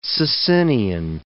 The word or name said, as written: Socinian